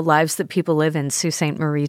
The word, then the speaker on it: Sault Ste. Marie: And Sault Ste. Marie, by the way, try to pronounce it the way the French would have pronounced it, Sault de Sainte Marie, which the French word S-A-U-L-T, at least in Old French, meant falls or rapids.